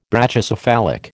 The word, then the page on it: Brachycephalic: American English